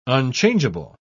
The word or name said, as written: unchangeable